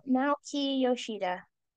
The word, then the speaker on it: Naoki Yoshida: Nayaki, is that how you say his name? Yoshidi? – Naoki Yoshida. – That's right.